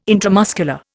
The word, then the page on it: intramuscular: Indian